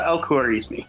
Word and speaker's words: al-Khwarizmi: I don't know how to pronounce that last one. – That actually wasn't bad. Would you like me to say it for you? – Sure. – It is Abu Abdullah Muhammad Ibn Musa al-Khwarizmi. – Khwarizmi, okay.